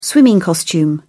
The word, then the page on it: swimming costume: British English